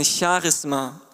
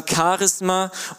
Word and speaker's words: Charisma: So wie wir es in der Bibel finden, wird es ausgesprochen, falls ihr es nicht lesen könnt, eine Charisma. Man kann auch sagen Charisma und dieses Wort begegnet uns auch in Charismatik.